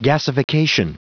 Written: gasification

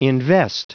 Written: invest

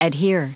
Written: adhere

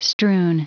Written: strewn

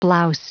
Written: blouse

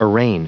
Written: arraign

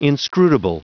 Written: inscrutable

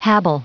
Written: habile

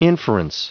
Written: inference

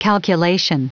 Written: calculation

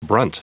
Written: brunt

